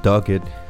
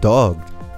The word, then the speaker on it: Dogged: I was saying Dogged. It is Dogged.